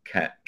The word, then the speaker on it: Cannes: Can, okay. – You could say con. Yeah, no one's saying con, sorry, but like can. – Can. – Yeah, but no S.